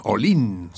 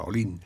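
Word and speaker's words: Ollin: Olin, se pronuncia, se escribe con doble L, pero se pronuncia con una sola, Olin.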